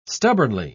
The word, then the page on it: stubbornly: British English